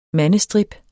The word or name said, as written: mandestrip